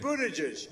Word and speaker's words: Buttigieg: You butchered his name, Joe. The one name you had to get right today. – Pete Buttigieg. – Buttigieg.